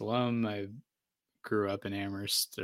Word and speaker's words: Amherst: And you're an Amherst guy, so that's. – Oof. Bad pronunciation there. – Amherst.